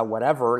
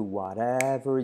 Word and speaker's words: whatever: Esa whatever, la R última casi no se dice, ¿vale? Make me walk, make me talk, do whatever you please.